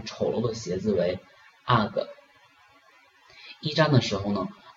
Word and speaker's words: UGG: 这里要注意一下这个 UGG U G G，我们国内呢通常都直接读成这个 U G G，那呢这个外国人呢是把它读成 UGG。